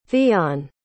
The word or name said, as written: theon